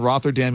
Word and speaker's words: Rotherham: But Rotherham is how you don't pronounce the ham, believe it or not.